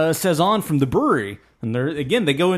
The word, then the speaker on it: Bruery: I wasn't even sure that I was pronouncing it correct when I called it The Brewery, because it just didn't seem logical that you would name the you would call your brewery The Brewery, but not, you know, but spelt that way, but still pronounced The Brewery.